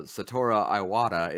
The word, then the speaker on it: Satoru Iwata: So if the president of Nintendo, Satoru Iwata, is listening.